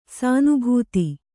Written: ಸಾನುಭೂತಿ

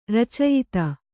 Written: ರಚಯಿತ

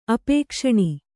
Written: ಅಪೇಕ್ಷಣಿ

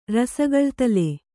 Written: ರಸಗಳ್ತಲೆ

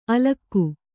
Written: ಅಲಕಿ

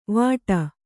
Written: ವಾಟಿ

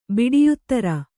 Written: ಬಿಡಿಯುತ್ತರ

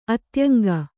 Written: ಅತ್ಯಂಗ

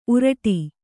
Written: ಊರಡಿ

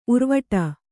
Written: ಉರ್ವಟ

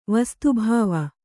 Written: ವಸ್ತುಭಾವ

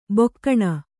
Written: ಬೋಗುಣಿ

